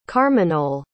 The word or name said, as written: carmagnole